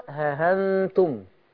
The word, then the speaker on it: ها أنتم: ها أنتم. – لا خلي بالك التسهيل معهوش مد، يعني تنطق. بصي أيوة، ها أنتم.